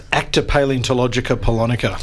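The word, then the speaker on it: Acta Palaeontologica Polonica: Because the paper that you've had your, I can't even pronounce it, but the paper, here we go, ACTA, I'll have to get you to help me here. – ACTA Paleontologica Polonica.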